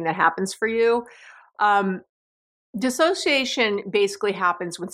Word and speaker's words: dissociation: Sorry, dissociation. It's not disassociation. It's dissociation.